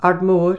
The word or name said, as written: ARDMORE